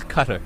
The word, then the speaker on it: Qatar: So at number two, it's either Qatar. – It's Qatar.